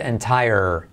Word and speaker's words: entire: Se escribe parecido, pero fíjate cómo se dice entire. Curioso, entire suena con rima como fire.